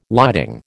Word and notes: lighting: American English

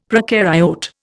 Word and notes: procaryote: Indian

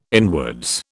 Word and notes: inwards: British English

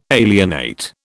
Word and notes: alienate: British English

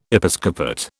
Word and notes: Episcopate: British English

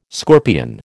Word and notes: scorpion: American English

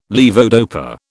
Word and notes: levodopa: British English

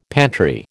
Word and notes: pantry: American English

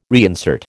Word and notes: reinsert: American English

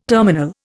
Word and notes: terminal: Indian

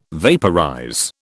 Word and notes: vaporize: British English

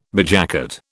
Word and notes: bedjacket: British English

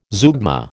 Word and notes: zeugma: American English

